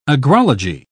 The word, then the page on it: agrology: British English